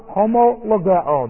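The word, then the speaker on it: homologeo (ὁμολογέω): This is the only place in the entire New Testament where this expression, confession of sins, with the verb homologeo, that's H-O-M-O-L-O-G-E-O, homologeo, and this has a rough reading, so that's an H-O sound, H-O-M-O-L-O-G-E, long O, homologeo,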